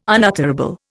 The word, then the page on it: unutterable: Indian